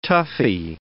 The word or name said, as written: toughie